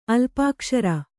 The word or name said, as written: ಅಲ್ಪಾಕ್ಷರ